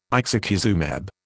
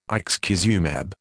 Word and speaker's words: ixekizumab: Active ingredient, ixekizumab. Phonetic pronunciation, ixekizumab.